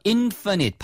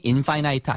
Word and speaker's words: infinite: 그러니까 F-I-N-이 끝이란 뜻이기 때문에. 근데 I-N-이 붙으면 반대말이니까 끝이 없다 해서 infinite, 발음이 infinite 아닙니다. infinite이에요.